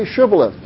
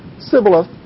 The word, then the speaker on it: Shibboleth: Well, say Shibboleth. He'd go, Sibboleth. And they'd go, huh? Well. And he would say Sibboleth, for he could not pronounce it right.